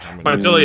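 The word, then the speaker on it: Pietila: Pitella? Is that how you say his name? – Pitella, yeah. – Pitella, yeah.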